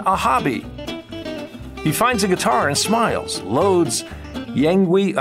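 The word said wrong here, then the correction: Yngwie: I'm sorry, how do you say it? Sorry? – Yingwei Malmsteen.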